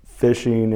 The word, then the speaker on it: phishing: Phishing is even though it's spelled with a PH, it is phishing for people's credentials is more or less what it's about.